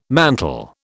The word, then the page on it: mantel: British English